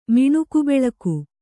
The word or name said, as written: ಮಿಣುಕುಬೆಳಕು